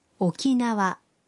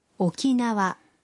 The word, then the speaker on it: Okinawa: El nombre del lugar se pronuncia Okinawa. Okinawa.